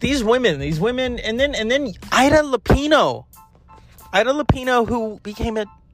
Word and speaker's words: Lupino: It was Ida Lupino.